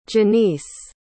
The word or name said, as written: jenice